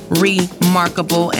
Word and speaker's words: Rimarkable: and find me under I Am Rimarkable. And Rimarkable is spelled what? With an I, not an E.